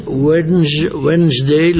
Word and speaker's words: Wednesday: En inglés, Wednesday, la DN no se pronuncia.